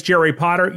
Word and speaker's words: Jerry Potter: My name is Jerry Potter. Yes, it rhymes with the boy wizard,